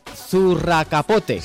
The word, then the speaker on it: zurracapote: Dice, lo mucho que echamos de menos, y es bastante conocido, sobre todo en la Semana Grande de Bilbao, es el zurracapote. – Sí, señor. – Lo he dicho bien, ¿no? – Sí.